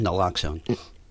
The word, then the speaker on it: naloxone: Narcan, naloxone.